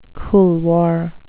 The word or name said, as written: couloir